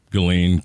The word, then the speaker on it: Ghislaine: Ghislaine Maxwell? – Yeah. Ghislaine.